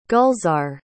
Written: gulzar

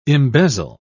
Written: embezzle

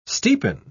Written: steepen

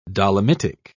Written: dolomitic

